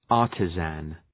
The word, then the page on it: artisan: ‘ɑ:rtızən